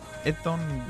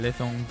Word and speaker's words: Eton: Bueno, y el primer deporte que os traemos hoy en la sección se llama Eton Wall Game. Es un juego que guarda ciertas semejanzas con el rugby y que se creó en el Eton College, un colegio de la ciudad de Eton, en Inglaterra.